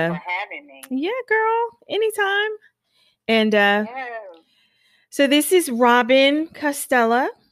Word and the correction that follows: Castiglia: Did I say that right? Castiglia. – Robin Castiglia. – Robin Castiglia of Ariel Tree of Life.